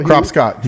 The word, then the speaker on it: Cropscott: Like when Bueno's teammate, Joe Cropshot, fought for me before. – Cropscott. He gets mad when you say it wrong.